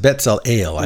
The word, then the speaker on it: Bezalel: Well, his name's pronounced Bezalel.